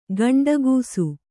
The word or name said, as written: ಗಂಡುಗೂಸು